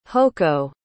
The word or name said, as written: hocco